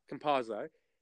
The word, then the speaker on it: Campazzo: And Campuzzo in particular, or Campazzo rather, I think I pronounced it wrong, Campazzo, he is an absolute magician with the basketball in terms of passing.